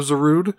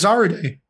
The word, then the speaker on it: Zarude: Though I did have to look up the pronunciation of Zarud because, you know, knowing, you know, this is a Japanese game. – I really thought it was Zarude.